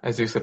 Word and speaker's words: Azusa: I'm very pleased again to be with an assistant professor at, is it Azusa? Is that the pronunciation? – Yeah, Azusa Pacific.